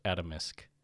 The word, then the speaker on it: Atomsk: Adamus. Is it Adamus? Am I saying it right? – It's spelled A-T-O-M-S-K, but I've heard Adamisk? Haruko pronounce it as Adamisk. – Okay, I'll just say Adamisk.